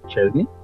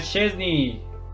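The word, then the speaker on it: Szczęsny: ¿Chesney? – Sí, muy bien. El polaco Wojciech, o bueno, como se pronuncia esa vaina, Chesney.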